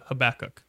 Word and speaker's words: Habakkuk: Um, but today we are talking about the problem of evil and specifically through the lens of the minor prophet Habakkuk. If you guys are not aware, Habakkuk is the way to pronounce it, not Habakkuk, because there's two K's. Excuse me. There's two K's. Habakkuk. So end of the the uh, disagreement or debate there.